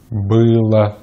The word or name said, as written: было